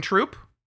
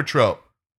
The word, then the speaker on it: troupe: Because you do have, we start our own just like acting troupe? – Is it troupe or trope? – Troupe. – I feel it's like acting trope. – No. – But it's still spelled the same. – No, acting troupe.